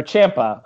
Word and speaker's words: Ciampa: Um, but yeah, we've said that we think Dijakovic will be, and then you've said you think Champa may also be, or Ciampa. I'm sorry, I don't. I hate that I pr- mispronounce his name like that. Ciampa will also be potentially a part of it.